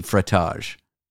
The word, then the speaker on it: frottage: Frottage. – Frottage. It rhymes with fromage.